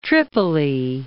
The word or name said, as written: Tripoli